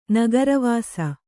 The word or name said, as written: ನಗರವಾಸಿ